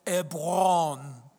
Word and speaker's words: Hebron: That's how you say it in Hebrew. Doesn't it sound cool when you say it like that? Hebron.